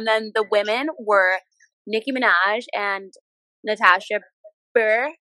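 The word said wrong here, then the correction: Bure: Beret. – Oh, yeah, I forgot. We literally just went through this, and I still said it wrong.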